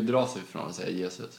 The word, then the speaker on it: Jesus: Gabriel Jesus i City. – Ja. – Som snyggt uttal där, annars får ni med mejla. – Ja, nej, Jesus, helt korrekt.